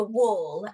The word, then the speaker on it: whorl: It's called a wall. And if I spell that for you, W-H-O-R-L.